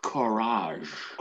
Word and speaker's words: courage: And again, it's pronounced courage. – Courage. Yes.